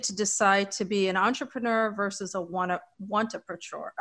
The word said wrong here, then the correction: wantrepreneur: I'm not saying that right. How do you say it, Michael? – A wantrepreneur.